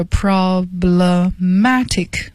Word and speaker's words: problematic: 我给大家拼一下，是 problematic，读音呢是 problematic， matic 是重音啊，这是有问题的。